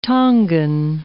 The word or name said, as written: Tongan